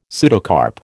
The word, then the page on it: pseudocarp: American English